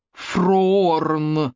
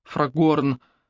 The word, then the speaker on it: Фрагорн: Это прозвище Руби дала ему потому, что он особо настаивал, чтобы его фамилию произносили именно как Фроорн, а не Фрагорн, как она на самом деле писалась.